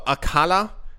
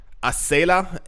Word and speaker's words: Acala: It's not popular news, but in the most talked about news, it says earlier today, Polkadot's DeFi hub, Akala, Acala, A-C-A-L-A, Acala, I don't know how you pronounce that, had its stablecoin, which is the letters A-U-S-D, exploited for about $1.2 billion.